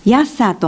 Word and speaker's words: 安久: 安久という地名の由来は、地元ではヤッサーと発音し、八つの沢から来ているとも、最初に村を開いた人の名前とも言われています。